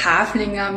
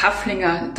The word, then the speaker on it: Haflinger: Also bei uns in Österreich wird das Wort Haflinger mit langem A ausgesprochen und deswegen hat es mich gewundert, dass ihr das so kurz aussprecht als Haflinger.